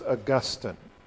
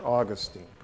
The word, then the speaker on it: Augustine: Augustine, or Augustine, pronounced both ways.